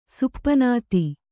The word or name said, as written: ಸುಪನಾತಿ